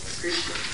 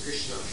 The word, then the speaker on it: Krishna: Hare Krishna, Krishna, two syllables, Krishna.